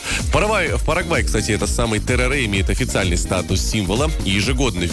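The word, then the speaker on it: tereré: Перелетаем с вами в Парагвай, и там нам уже подают с вами тереré. Вот именно так, с ударением на последний слог.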